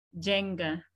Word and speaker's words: Jenga: My name is Jemima Diana Jenga. Jemima. – Okay. Jemima. – Diana. – Diana. – Jenga. – Jenga. Okay, so the N is silent.